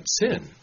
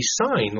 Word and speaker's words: Sin: And after I gave that sermon in Medford, a member down there came up to me and said, you know, I don't think that you pronounced that right, sin. I mean, it's a wonderful way to pronounce it. And if you use some of the Bible reading programs that actually read to you, it says sin. It doesn't pronounce it a different way. But he says, you know, I think it's supposed to be sign, like in Sinai.